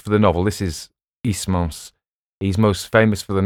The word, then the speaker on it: Huysmans: Never know how to say that because it's a Dutch name, but he was French. Huysmans. And as far as I can see, the French pronunciation is Huysmans.